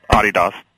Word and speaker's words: Adidas: The client list for TBWA includes Apple, Nissan, PlayStation, Visa, Absolute, Adidas, which I think, am I saying that wrong? – Adidas.